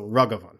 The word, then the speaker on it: Raghavan: The vowels are usually going to be pronounced the same in a name. So it's the A's in this are pronounced like with an emphasis on the first syllable. So Raghavan. It's weird to say it without the little bit of the rolling R.